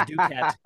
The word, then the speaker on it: Duquette: Uh, yeah, once again, my name is Alex Duquette. I know a lot of people online probably see that last name and they think, how do you say that? But yeah, it's just, it's, it's Duquette.